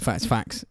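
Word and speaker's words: Faxe: Fact, I don't know how you pronounce that because it's F-A-X-E. – Danish. – F-A-X-E. I'm going to say facts. – Yeah, facts. It's like some sort of like Danish Viking beer, but it's a 1 litre can of 10% madness.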